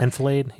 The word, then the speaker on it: enfilade: Enfilade? Yeah. – Enfilade. According to the dictionary, that's how you say it.